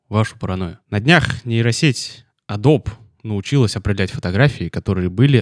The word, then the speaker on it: Adobe: Однажды ребята из Adobe сказали, что правильно говорить Adobe, и с тех пор мир мой изменился.